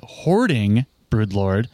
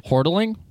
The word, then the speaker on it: Hoarding Broodlord: Hoardling? – Hoardling. Every person says Hoardling. There's no L on this.